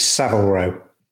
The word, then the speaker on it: Savile Row: Pronunciation point. You might want to redo that first line. It's Savile Row.